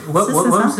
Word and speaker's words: Wham: Alors, je sais même, là, pour le coup, c'est pas un mot chinois, mais je sais même pas si je le prononce bien, son nom de groupe. Wham, c'est ça.